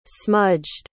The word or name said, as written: smudged